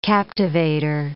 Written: captivator

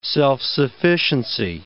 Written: self-sufficiency

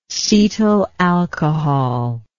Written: cetyl alcohol

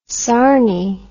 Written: sarnie

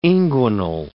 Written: inguinal

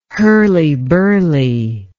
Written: hurly-burly

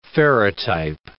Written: ferrotype

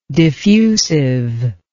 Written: diffusiveness

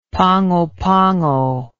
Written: Pago Pago

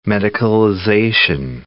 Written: medicalization

